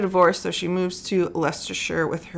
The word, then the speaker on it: Leicestershire: Leicestershire. – It's not spelled like that, but I looked it up. That is how you say it.